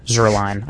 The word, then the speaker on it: Zuerlein: The other factor could be Steven Gostkowski and Greg the Leg Zuerlein. I think that's how you pronounce his last name. – Yes.